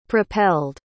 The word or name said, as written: propelled